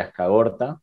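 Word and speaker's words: Azkargorta: Ahí era la figura de la Bolivia de Azcagorta, se pronuncia así.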